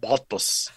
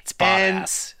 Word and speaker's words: Bottas: You know, they got rid of Bottas, as everyone makes me say. They're like, it's you're terrible at pronouncing it. It's Bottas. Yeah. So it's Bottas. That's what it is.